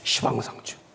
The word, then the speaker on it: 시방: 열십자 모방자 해야 되는데 불교는 십방하니까 발음이 안 좋잖아요. 시방 이래요. 시방. 시방.